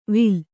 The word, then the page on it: ವಿಲ್: vil